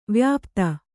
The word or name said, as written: ವ್ಯಾಪ್ತ